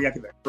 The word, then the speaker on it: Yankovic: Weird Al Yankovic, correct.